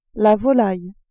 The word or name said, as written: volaille